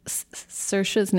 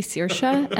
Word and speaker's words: Saoirse: Well, first I have to compliment you because you pronounce Saoirse's name right. You say Saoirse, and that's actually the Irish way to say it.